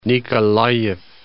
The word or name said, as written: Nikolayev